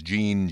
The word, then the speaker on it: Gean: And I had said John, but I mean, his name is spelled G-E-A-N, and it's pronounced Gene Giannini.